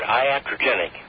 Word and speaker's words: iatrogenic: Used the word iatrogenic, right? – Maybe I mispronounced that.